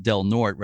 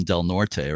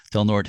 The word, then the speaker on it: Del Norte: So the lens, as you just said, is in the Del Norte, and you pronounce it Del Norte rather than Del Norte, right? – Yes. – Yes. Yeah. Okay. Del Norte Historical Society.